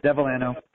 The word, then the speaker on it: Devellano: Close, yeah, Devellano.